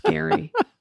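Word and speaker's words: Gerry: Yeah, his name rhymes with Larry. – Yeah. – It like annoys me so much that it's spelled like Jerry, but it's pronounced Gary.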